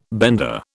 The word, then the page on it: bender: British English